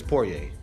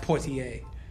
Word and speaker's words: Poirier: A lot of people say his name wrong, like Poirier. It's Poirier. – Poirier. – That's how you say There's no T in there. – Nah. – It's not Poitier.